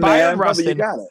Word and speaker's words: Bayard Rustin: If you're just tuning in, we're talking to Coleman Domingo, Rustin, who plays, and I want to make sure I get the pronunciation right. Bayard Rustin, is that the proper name? You got it, man. Bayard Rustin.